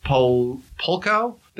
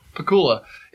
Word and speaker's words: Pakula: Is it Polkow? The guy who did All the President's Men? – Pakula.